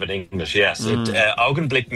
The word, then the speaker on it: Augenblick: Actually, am I saying that right? How do you say that? Is it Augen? – It's called Augenblick. – Augenblick. – Augenblick. – Yeah,